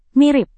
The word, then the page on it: mirip: Indonesian